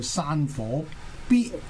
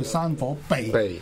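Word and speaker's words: 賁: 直年卦嚟講就叫山火逼或者叫山火避啊，即係因為呢個字咧就好多讀音嘅。咁啊，因為普通話嚟講咧，佢直情係同呢個墳墓個墳咁嘅讀音。因為廣東話咧就應該就係避啦或者逼咁樣讀法。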